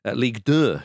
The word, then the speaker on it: Ligue 2: But Amiens are currently 10th in League 2. Ligue 2, should I say, as we all say Ligue 1, so keep it French.